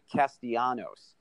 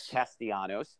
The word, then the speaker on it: Castellanos: and that was the acquisition of Nick Castellanos from the Detroit Tigers. Excuse me, I'm mispronouncing his name. It's Nick Castellanos. – There you go. – The L is silent. Castellanos. And Grammar's important on this podcast.